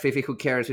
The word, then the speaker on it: Fifi Hoo-Kers: At every social media you can think of, at Fifi Who Cares, Fifi Who Cares spelled with an F. Who Cares, it's spelled like a hooker.